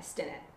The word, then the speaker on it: Stinnett: I keep trying to say it as a long I. Stinnett.